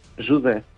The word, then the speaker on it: José: Pero José en portugués, José. – Jude. – ¿Cómo? – Jude. – Jude. – Jude. – Pues como Jude, como dice Jude.